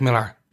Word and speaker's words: Millar: Miller World are self-contained stories. – Yeah. – About certain heroes. The only connection is written by Mark Millar, or Miller, depending on how you want to pronounce his name.